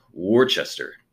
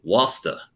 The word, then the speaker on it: Worcester: I went up to, I pronounce it Worcester, but they said it's Wasta.